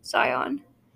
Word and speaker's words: Xeon: Xeon. – Thank you. – Yes. – One little grammatical error, it's pronounced Zion.